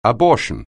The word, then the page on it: abortion: anglais